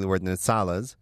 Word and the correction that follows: Izala: are Shia. – Okay, Izalas.